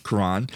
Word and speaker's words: Quran: All right, when it comes to the Muslim faith, the followers of the Quran, is that how you say it? – Quran, believe that it is the holy word of God.